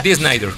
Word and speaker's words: Dee Snider: Y la verdad es que, por ejemplo, ahí el señor Snider, pues bueno, Snider, ¿cómo se pronuncia? – Dee Snider.